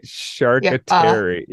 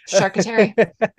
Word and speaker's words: charcuterie: Right, and I was trying to get it phonetically to be enough to spell out a shark, Terry. – S-H-A-R-K, right? Charcuterie. Yeah, that's hilarious. – Charcuterie.